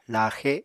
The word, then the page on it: laaxe: Apatani